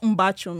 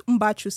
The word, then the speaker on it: Mbachu: That's what Mbachu means.